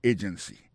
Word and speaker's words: agency: Pour mot agency, hein, accent tombé sous première syllabe là. Agency. Deux autres syllabes qui sont derrière eux, faible.